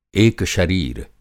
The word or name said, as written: एकशरीर